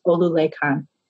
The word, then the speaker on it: Olulekan: A simple statement like, I know many people have been calling Olulekan Olu for a while, but his name is correctly pronounced Olulekan.